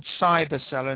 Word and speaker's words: Cybercellar: Yeah, and the other thing is there is a company in South Africa called Cyberseller, and that's spelled CYBERCELLAR.